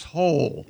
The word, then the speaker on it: Hole: But it's pronounced Hole. – Yeah, Ole. – Detective Ole. Hole. Yeah. – It's spelled H-O-L-E. Detective Hole.